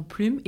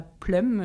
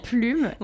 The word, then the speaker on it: plume: Du coup, on peut se mettre d'accord direct, poser les bases tout de suite, qu'on prononce bien plume et pas plume euh, à l'anglaise. Nous, c'est vrai ici qu'en français, on dit plume.